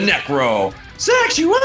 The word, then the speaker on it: Necrosexual: And well, I have to stop you for a second, Big Will, because you actually mispronounced my name. It's the Necro Sexual. You got to get the falsetto.